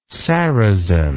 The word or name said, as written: sarazen